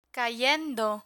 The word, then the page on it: cayendo: spanish